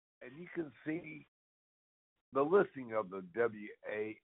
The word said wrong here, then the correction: WMARA: Am I saying it right, Breck? What is it? W-A? – Yes. W-M-A-R-A. – Oh, I missed that. It's W-M-A-R-A.